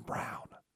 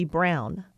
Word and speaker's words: von Braun: He was German, so in German it would be Braun. But because in English people look at AU and they think ah, so English-speaking people mispronounce it by saying Braun.